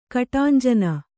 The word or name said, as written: ಕಟಾಂಜನ